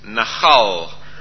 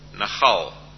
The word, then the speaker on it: Nachal: It is Nahal with a L on the end. L for Leonard. Nahal. And it's spelt N-A-C-H-A-L.